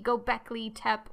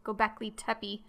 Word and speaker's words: Göbekli Tepe: the pronunciation of the archaeological site I was talking about is supposed to be Göbekli Tepe or Göbekli Tepe, I was mispronouncing it the entire time, basically.